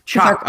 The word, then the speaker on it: Chak: Is it Tijak? Tijak? – Oh, Chak? – Chak, okay, so I was just way off.